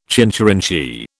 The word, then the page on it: chincherinchee: British English